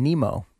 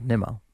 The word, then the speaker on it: Nimmo: Apparently the A's broadcast was saying Brandon Nemo instead of Nimmo.